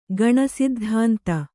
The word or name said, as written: ಗಣಸಿದ್ಧಾಂತ